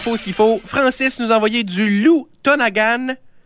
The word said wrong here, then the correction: Louis Tonagan: Je le prononce-tu bien? C'est Louis. Louis Tonagan.